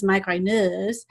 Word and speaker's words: migraineurs: uh, we're called migrainers or it's spelled in the french way and i took french so in my mind it was migraineurs